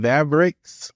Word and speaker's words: Vavrix: So y'all make sure you like, comment, subscribe, go follow my man Vavrix. I said that correctly? – Yes, you did.